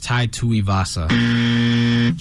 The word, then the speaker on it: Tuivasa: Tell me if I'm wrong, Tai Tuivasa and Alexander Volkov. – Volkov was correct. It's Tai Tuivasa.